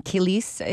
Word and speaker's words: Kilis: You met them mostly in Kilis, is that how I pronounce it, in Turkey? – Yes.